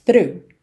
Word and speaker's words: through: Through, lembrando, palavra sem mistério, embora a escrita dela seja THROUGH, a pronúncia é simples. Through.